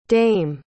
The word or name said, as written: daimh